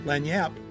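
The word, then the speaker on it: lagniappe: Today we're diving into the concept of what's called Lanyap. Lanyap, pronounced as Lanyap, is a Creole term that means an extra or unexpected gift.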